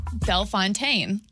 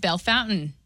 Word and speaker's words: Bellefontaine: I said the word Bellefontaine. – Yes. – And all my friends hit me up and they said, girl, it's Bellefountain. And I said, that's not how it's spelled.